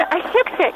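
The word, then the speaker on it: осёкся: Или, допустим, он осекся или осекся? – Пресекся и осекся. – И там не чередуется? – Они чередуются следующим образом. Под ударением «ё», без ударения «е» пресекать, но пресек.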